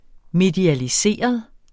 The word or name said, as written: medialiseret